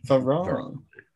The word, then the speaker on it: Varane: A problem if they sign Varane and Sancho.